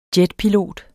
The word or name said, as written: jetpilot